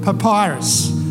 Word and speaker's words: papyrus: When she couldn't hide him any longer, she got a little basket boat made of, how do I say this word? Papyrus, right? Papyrus. I just wanted to see, because I was saying it wrong last night.